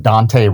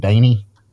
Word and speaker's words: Dante: In Powell, there's a Dante Road, but everybody calls it Daney.